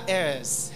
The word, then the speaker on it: heirs: Because we are sons, we are heirs. Heirs. Is the H pronounced or silent? Heirs.